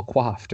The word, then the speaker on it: coiffed: Their hair is well quaffed or. Now I'm. Oh, because I'm spelling it wrong. COIFFED. That is also, it's pronounced the exact same way, but they mean completely different things.